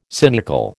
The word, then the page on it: cynical: American English